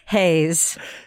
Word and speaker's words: Reis: Yeah, it's like R-E-I-S. – I know. I don't know if you guys knew this at home, but an R in Brazil is pronounced H, so it's not even Rio. – If it's at the beginning of a word.